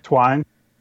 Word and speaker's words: Twyne: Roosevelt, T-W-Y-N-E. How would you say that? Twine? – Twine.